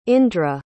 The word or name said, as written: indra